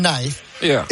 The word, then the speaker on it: knife: The letter K in knife is silent because people used to pronounce it in Old English and Old Norse. And the spelling stayed the same even after the pronunciation changed.